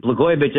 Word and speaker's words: Blagojevich: Before I do that, let me just explain that John Katzimatidis, Blagojevich isn't hard to say. It rhymes with Katzimatidis.